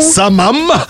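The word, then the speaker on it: сомам: Самы, сама, Сама. само, Самах. самах, саму. Самам. Это как хамам. Да, самам.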